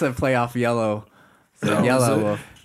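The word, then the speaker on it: Yelawolf: It's Yella Wolf. – Yella, is it? – Yeah.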